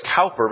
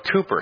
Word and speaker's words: Cowper: It looks like it's Cowper, but it's actually pronounced Cooper.